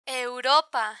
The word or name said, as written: europa